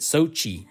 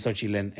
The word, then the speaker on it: Xochitl: So her name is Xochitl, X-O-C-H-I-T-L in the movie, but everyone pronounces it Sochi. Everyone. Yeah, that bothered me. – It bothered me too because... – Sochi. – I'm like, that's not how you say that name. Maybe that's how you say it in California or something. I don't know. But I have a cousin named Xochitl. It's Xochitl with an L at the end.